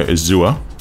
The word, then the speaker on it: Azure: You can pronounce it two ways, too, if you want. You can go Azure or Azure. It doesn't really matter. It's still the same thing. – That's right. Spelt the same way, just a different pronunciation of the vowel.